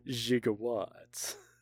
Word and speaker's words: gigawatt: The piece of trivia I found is in Love Languages, it's still pronounced with a soft G. Gigawatt. Gigawatt? Yeah.